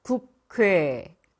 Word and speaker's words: 국회: 국회, 국회. 아, 아니죠. 국회. 네, 맞습니다.